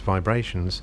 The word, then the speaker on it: VIBErations: Hi, this is my weekly podcast pronounced Vibrations but spelt Vibe Rations.